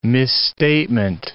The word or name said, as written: misstatement